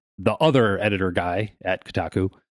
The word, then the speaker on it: Kotaku: Yep, from Kotaku. – I always thought it was Kotaku. Is that how you say it? – I'm pretty sure it's Kotaku.